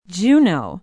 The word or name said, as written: Juneau